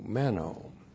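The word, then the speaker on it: meno (μένω): M-E-N long O, meno. So here's our old friend, meno, repeated in the second half of verse 9.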